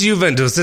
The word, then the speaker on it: Juventus: Is it Juventus? How do you say the damn name? – It's Juventus.